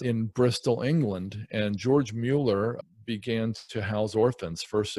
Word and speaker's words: Mueller: His name's George Mueller. It's technically pronounced Mueller.